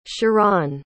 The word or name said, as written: charan